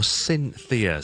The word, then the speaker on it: Cynthia: 琴日同大家讲咗 Cynthia C Y N T H I A 嘅英文名字嘅英文发音啦，Cynthia。